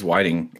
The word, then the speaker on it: Whiting: No, you guys are right. It is Whiting, according to my again, 3 years of dyslexia class.